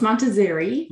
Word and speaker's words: Montazeri: Is that, did I pronounce that right? Montazzari? – Close, Montazzari.